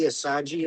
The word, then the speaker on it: Assad: Assad, A-S-S-A-D, Assadji is how they pronounce it.